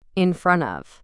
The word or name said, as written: in front of